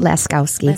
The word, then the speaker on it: Laskowski: Am I saying that correctly? – Laskowski. – Laskowski. Michelle Laskowski. Is that Polish? It is.